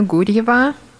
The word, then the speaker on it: Gurieva: My name is Lana Gurieva.